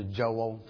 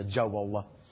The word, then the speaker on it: Joel: My name takes on more syllables the farther south I go. It goes from Joel to Joel to Joel. Okay, so that's how I know how deep I am in the heart of Texas, is how many syllables my name has.